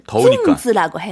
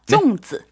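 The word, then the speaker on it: 쫑즈 (粽子): 단오절에 먹는 음식은 쫑즈라고 해요. 쫑즈. 네? – 쫑즈. – 네, 우리 중국어 배울 때는 그 아들자 강성 등장하잖아요. 네. 네, 경성어 발음하시면서 쫑즈.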